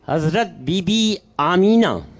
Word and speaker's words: آمنہ: اس کا صحیح تلفظ ہے آمینہ. آمینہ. تو جو آمنہ آمنہ کر رہے تھے، یہ آمنہ تلفظ جو ہے یہ صحیح نہیں ہے.